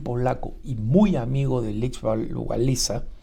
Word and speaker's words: Lech Wałęsa: Probablemente el nombre de Lech Walesa, o como se pronuncia en polaco, Lech Wałęsa,